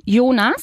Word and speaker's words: Jonas: En alemán ese nombre lo pronunciamos Jonas.